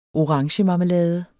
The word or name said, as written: orangemarmelade